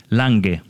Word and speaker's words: Langhe: Is it Langhe? – Lange. – Lange. Okay, Lange.